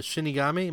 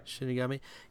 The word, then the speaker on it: Shinigami: Willem Dafoe is the voice of the Shinigami. Am I saying that right? – Yeah. – Shinigami.